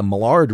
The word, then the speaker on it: Maillard: It's spelled M-A-I-L-L-A-R-D. The D is silent, so don't call it the Maillard reaction. And it's not the Maillard reaction.